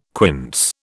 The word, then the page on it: quince: British English